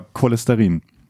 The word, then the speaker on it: Cholesterin: Folgt auf ein Ch am Wortanfang ein Konsonant oder ein sogenannter hinterer Vokal, also ein A, O oder U, dann wird das Ch als K ausgesprochen, wie ein Chorcharakter oder Cholesterin.